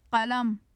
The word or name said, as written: qalam